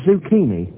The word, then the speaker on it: zucchini: Z-U-C-C-H-I-N-I. – Z-U-C-C-H-I-N-I. Thanks. How do you pronounce it? – Zucchini. The stress is on the second syllable.